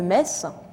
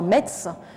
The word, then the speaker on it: Metz: On pourrait prendre aussi l'exemple de Metz, que les étrangers appellent parfois Metz, en faisant une ville allemande, ce qui est effectivement, du coup, vraiment une injure épouvantable pour les Mosellans.